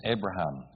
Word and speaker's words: Abraham: It's you, it's Avram, but we're going to add a few syllables, change the pronunciation, it's Abraham. When God makes you born again, it's still you, but then it's a new you.